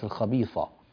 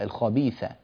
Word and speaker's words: الخبيثة: الخبيثة، الثاء هنا مرققة، يعني لسانك سبق ونطقت معك مفخمة وصارت الخبيثة، لا، الخبيثة، الخبيثة، رقق الثاء.